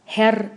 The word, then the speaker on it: Herr: Si el interlocutor es un hombre, le van a decir señor Maya, o sea, Herr Maya. Y esta doble R del final de Herr muchas veces nos la comemos y entonces decimos, Herr.